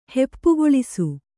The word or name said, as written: ಹೆಪ್ಪುಗೊಳಿಸು